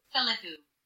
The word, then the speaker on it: Philippou: How to pronounce? Yeah, here we go. Oh, sorry. – Philippou. – Okay. Philippou. – Philippou. – Close enough. – Close enough, yeah.